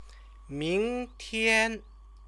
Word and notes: mingtian: Chinese

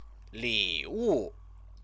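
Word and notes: 礼物: lĭwù

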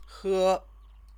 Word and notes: he: Chinese

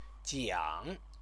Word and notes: jiang: Chinese

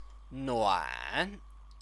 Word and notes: nuan: chinese

